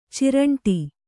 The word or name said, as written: ಚಿರಂಟಿ